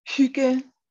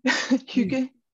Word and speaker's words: hygge: Hygge. Yeah, the Hygge. Y is like very Hygge.